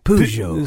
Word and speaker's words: Peugeot: So here is the official pronunciation: Peugeot. – So it should be Peugeot.